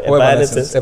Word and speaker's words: Evanescence: Eh, eh, Evanescence. Sí, Evanescence.